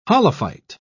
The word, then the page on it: holophyte: British English